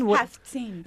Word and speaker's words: haftseen: Can you tell us, and is it a haftseen? – Haftseen. Yes. – Which is spelled, by the way, H-A-F-T-S-E-E-N. So listeners, that's a new word for you.